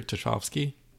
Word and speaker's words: Tchaikovsky: Hoffman that was turned into a two-part ballet by Peter Tchaikovsky.